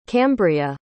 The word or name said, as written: cambria